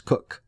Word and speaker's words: Coke: If you read, however, the commentary of Sir Edward Coke, and Coke is spelled like Coke, C-O-K-E, but it's pronounced Cook.